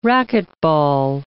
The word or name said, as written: racquetball